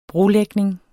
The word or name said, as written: brolægning